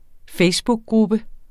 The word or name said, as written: facebookgruppe